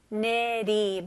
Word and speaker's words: eternity: 네, 스펠링은 E지만 I로 생각하시면 좋을 것 같아요. eternity. 마지막 T는 아주 가벼운 D로 발음하시면 훨씬 더 쉽게 발음할 수 있어요.